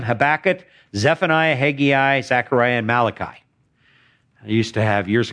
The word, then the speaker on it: Malachi: All right, that's the way you pronounce it, Malachi.